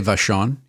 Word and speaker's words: Vachon: How do you pronounce it? Is it Vachon? – I would say Vachon.